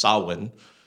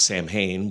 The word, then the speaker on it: Samhain: which is pronounced Samhain, not Samhain, which a lot of people think it is.